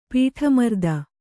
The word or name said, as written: ಪೀಠಮರ್ದನ